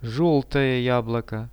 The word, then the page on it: zheltoe jabloko: Russian